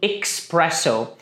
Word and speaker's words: espresso: Algumas pessoas nos Estados Unidos costumam colocar um X aí na pronúncia falando espresso. Mas a pronúncia mesmo é espresso.